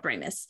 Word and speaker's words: Ramis: And that was be a nod and a love letter to Harold Ramis.